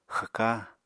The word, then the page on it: xwka: Apatani